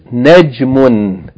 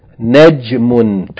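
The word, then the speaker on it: Najmun (نجم): The next word is Najmun. Najmun. Two syllables.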